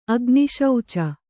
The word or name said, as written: ಅಗ್ನಿಶೌಚ